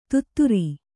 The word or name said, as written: ಧತ್ತೂರ